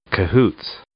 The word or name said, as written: cahoots